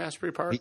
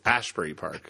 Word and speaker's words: Asbury Park: I like to mispronounce it, which I just did as Ashbury Park. – Ashbury Park. – Yeah, that's what native New Jerseyans say when you're from the Garden State.